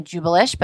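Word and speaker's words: Jewbellish: I was pronouncing it wrong. I was calling it Jubelish, but now I know to call it Jubelish, so emphasis on the bell.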